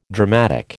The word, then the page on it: dramatic: American English